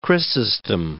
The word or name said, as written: Chrysostom